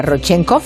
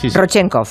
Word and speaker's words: Rodchenkov: Enseguida nos cuenta que es una ley llamada Rodchenkov. ¿Lo he dicho bien? – Sí. – Rodchenkov, sí.